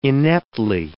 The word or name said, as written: ineptly